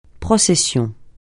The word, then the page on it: procession: francais